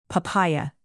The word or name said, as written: papaya